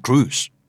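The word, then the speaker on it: Cruise: ยังมีคนพูดทอมครุยอยู่แล้วก็ออเรนจุยนี่ก็ได้ยินตลอดนะครับทั้งที่จริงๆแล้วเจ้าของภาษาจะออกเสียงเป็นอูสเช่นทอมครูซแล้วก็ออเรนจูสน้ำผลไม้คือจูสและนามสกุลของทอมคือครูซน้ำส้มครับออเรนจูสดาราฮอลลีวูดครับทอมครูซ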